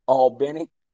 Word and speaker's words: Albany: My grandparents buying a fried chicken shack down in Albany, Georgia, pronounced Albany.